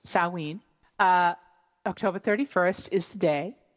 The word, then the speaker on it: Samhain: It looks like Samhain, but it's Samhain.